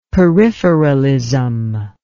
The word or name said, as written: peripheralism